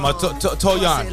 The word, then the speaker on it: Touyaun: And that's exactly how I pronounced them. Toyon.